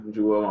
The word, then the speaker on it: Jewell: Jewels. – What's going on? – What's good? So how do you say your name? Is it Jewell? – It's either or, for real. My dad called me Jewell.